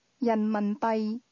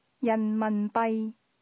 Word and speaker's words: 人民币: 人民币。币。人民。人民。人民币。 – That's pronounced with two mid-falling tones, followed by a low level tone. – 再讲一次。 – 人民币。